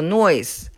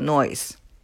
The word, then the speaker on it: Noyes: 嗯，这对夫妻俩他们的姓儿非常有意思，叫做 Noyes，就是 N O Y E S。 no 加一个 yes，啊，这是 no yes，但是发音叫 noise。